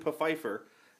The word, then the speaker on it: Pfeiffer: The story, the show centers around a black English nobleman named Desmond Pfeiffer. And the F is, in fact, pronounced.